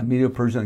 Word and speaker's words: Medo-Persian: and then the Medo-Persian Empire, and then the Grecian Empire, and then the Roman Empire, and so forth. Did I say that right? Yeah, Medo-Persian and Grecian.